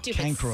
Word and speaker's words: chancroid: C-H-A-N-C-R-O-I-D. – Cancroid. Silent H.